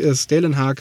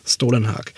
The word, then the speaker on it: Stålenhag: Wer ist dieser Mann und wie wird er eigentlich richtig ausgesprochen? So, ne? Und dann ist es eben nicht Stålenhag, sondern Stålenhag. Genau,